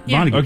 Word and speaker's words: Vonnegut: Kurt Vonnegut? – Yeah, Vonnegut.